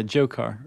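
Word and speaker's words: Dzhokhar: And then the one, I guess, is that Zokar? The D is silent, right? – Yeah, Jokar.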